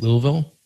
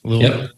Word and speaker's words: Louisville: And if you're looking for a new agency in, how do you say it, Louisville? – Did I say it right? That's perfect. – Louisville, Louisville, Kentucky.